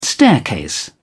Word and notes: staircase: British English

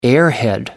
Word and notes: airhead: American English

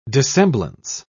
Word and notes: dissemblance: British English

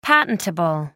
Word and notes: patentable: American English

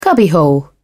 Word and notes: cubbyhole: British English

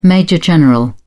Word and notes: major-general: British English

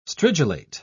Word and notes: stridulate: British English

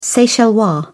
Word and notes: seychellois: British English

